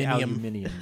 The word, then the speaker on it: aluminium: If you're British, you say aluminium.